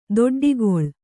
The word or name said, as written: ದೊಡ್ಡೊಕ್ಕಲು